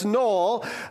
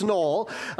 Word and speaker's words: Noel: So it's Noel. So it's spelled the same as the first Noel, but it's not. It's Noel.